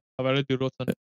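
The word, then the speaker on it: Javairô Dilrosun: Javairo Dielrosen? Is that correct? – Yeah, Javairo Dielrosen. Yep.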